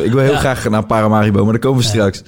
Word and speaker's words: Paramaribo: Maar toen zei ik Parimaribo. – Oh, ja. Nee. – Dus die heb ik heel veel van Surinaamse mensen om mijn oren gehad. Van, yes, leer eerst even die stad goed uitspreken.